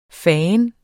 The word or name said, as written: fandens